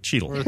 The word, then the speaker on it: Cheadle: Like Cheadle, like a beetle with a chi in front. Cheadle. – Thread the needle. – All right. So thread the Cheadle.